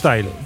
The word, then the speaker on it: Turnstile: Turnstile.